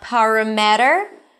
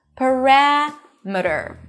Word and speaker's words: parameter: And finally, let's take the suffix meter, meter, as in kilometer, parameter, not parameter. The stress falls on the one syllable before the suffix, and suffixes are usually reduced. Parameter,